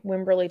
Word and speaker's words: Wimberley: W-I-M-B-E-R-L-E-Y, Wimberley, Texas. – Wimberley, Texas.